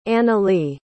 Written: analee

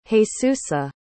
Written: Jesusa

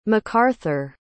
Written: mcarthur

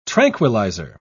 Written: tranquilizer

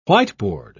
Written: whiteboard

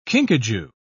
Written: kinkajou

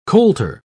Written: colter

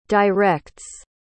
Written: directs

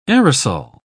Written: aerosol

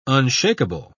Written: unshakable

